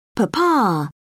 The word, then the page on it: papa: British English